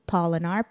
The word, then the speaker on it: Polinar: Oh, Melissa Polinar, M-E-L-I-S-S-A. My last name, Polinar, P-O-L-I-N-A-R, rhymes with pollination, kind of, not really.